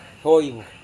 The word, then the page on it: hoivo: Serewen